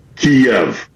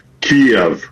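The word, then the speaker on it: Kyiv: The Ukrainian closest approximation that we can do as non-Slavic speakers is Kiev. So the Y ends at the first syllable.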